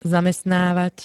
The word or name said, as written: zamestnávať